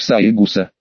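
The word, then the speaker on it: Саэгуса: Ах, извини, я, президент школьного совета первой старшей школы, Саегуса Маюми, пишется как семь трав, читается как Саегуса.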